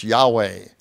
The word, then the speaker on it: Yahweh: And so in older translations, it was translated as Jehovah, as trying to transliterate the Hebrew into English letters, J-H-V-H. Scholars now think probably a better designation would be, like if we'd spell it, Y-E-H, W-A-H, Yahweh.